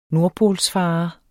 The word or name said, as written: NORDPOLSFARER